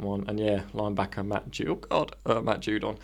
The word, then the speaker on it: Judon: Just in case you don't know how to pronounce Aguilar as well, it's pronounced Judon. – But in capitals, JOO-DON small. wide receiver Nelson Aguilar.